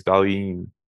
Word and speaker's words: Dahlin: When we talked to Jimmy Hamrin a while ago, and I was like, how do you pronounce it? He's like, it's Dahlin.